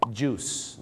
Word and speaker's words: juice: juice นะครับผม juice ไม่ได้ออกเสียงว่าจุ้ยนะฮะ